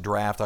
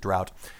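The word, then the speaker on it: draught: I know it's draft. It's supposed to be pronounced draft. I always pronounce it drought.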